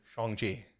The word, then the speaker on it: Shang-Chi: It's actually Shang-Chi.